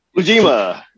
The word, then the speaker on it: Ujima: Ujima.